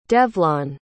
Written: devlon

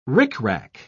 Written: rickrack

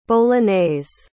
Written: bolognese

